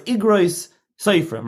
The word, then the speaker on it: Igros Seferim: So it's Igros Seferim, right? – It's Igros, the plural, like the letters of Seferim. But the name is actually written with a malay, you know, it's Aleph Yud Gimel.